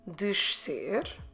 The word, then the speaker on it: descer: So from the top, pronouncing the sh and the su together. Descer. Eu desço, tu desces, você desce, ele desce, ela desce, nós descemos, vocês descem, eles descem. Elas descem. So I pronounce correctly the S as a sh and the C as a su.